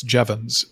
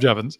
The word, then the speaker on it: Jevons: You will now tell me it's Jevons, and then I'll... – All right. So we'll call him Jevons.